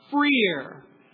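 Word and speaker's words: freer (φρέαρ): And by the way, the word for well in verse 11 and 12 is the Greek word freer. If you were to anglicize it, I would spell it P-H-R-E-A-R.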